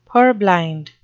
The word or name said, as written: purblind